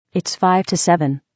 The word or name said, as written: 5 to 7